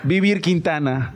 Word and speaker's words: Vivir Quintana: Y Vivir Quintana.